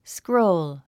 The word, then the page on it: scroll: skróul; スクロウル